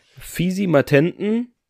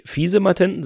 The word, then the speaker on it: Fisimatenten: Wir haben jetzt gelernt, Kriepe, sächsisch für gemeiner Mensch, Fisimatenten ist nicht Fiesematenten, sondern Fisimatenten, vielleicht auch Fisimatenten ausgesprochen, keine Ahnung, für Blödsinn machen.